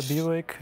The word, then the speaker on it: Buick: Den Lincoln, genau, dann den Buick, gell, habe ich richtig ausgesprochen? – Ja, der Buick, der Lincoln, ein Oldsmobile.